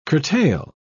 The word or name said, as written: curtail